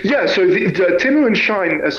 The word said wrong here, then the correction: Shein: Shein has been in the country for a while, and they've kind of become known as like a fast fashion type outfit.